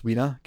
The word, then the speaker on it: Weena: But there is definitely a couple of moments with this love interest later on, this Weena character, which, by the way, Weena, what a stupid name. That's spelled, for the record, W-E-E-N-A, Weena.